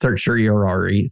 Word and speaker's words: certiorari: The application for stay pending certiorari. Let me redo that. – That's not a word you really need to know how to pronounce on this podcast. – Certiorari. Certiorari. Yeah.